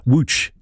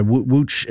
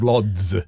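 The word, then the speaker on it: Łódź: Wooch is the name,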